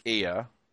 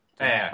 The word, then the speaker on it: Eyre: I think it's just that North Star Miniatures, Nick Ear. Is it Ear? Is that how you pronounce it? – Air. – I think. Air. – It's like Jane Eyre, isn't it? – Oh, is it?